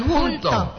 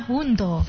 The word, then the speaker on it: hundo: Bukan hunto. – Iya, halusnya hundo.